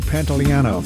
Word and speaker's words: Pantoliano: And I'm really excited about, even though I'll never go to this thing, Joey Pantalonio. Am I saying his name right? I'm not saying his name properly, am I? Joey Pants. You know this guy? Joe Pantoliano, thank you, that's it.